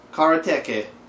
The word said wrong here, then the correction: Karateka: I was saying that right? – I don't think so. – Karateke? – Pretty sure it's Karateka.